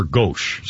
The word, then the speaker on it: gauche: She wore a silhouette of clothes that were extraordinary, but somewhat gauche. Or gauche, is how I pronounce it.